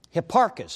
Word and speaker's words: Hipparchus: Hipparchus, he sounds almost like hippopotamus kind of, and that's kind of what I think of anyway.